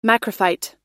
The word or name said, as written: macrophyte